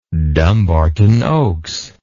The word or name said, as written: dumbarton oaks